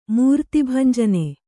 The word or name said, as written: ಮೂರ್ತಿಭಂಜನೆ